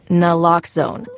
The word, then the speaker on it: naloxone: For the record, it's Naloxone. Nuh, like, is Naloxone addictive? Nuh-uh. Lox, like Naloxone keeps humans safe, like locks keep bikes safe. And own, like Naloxone can save lives in our very own communities.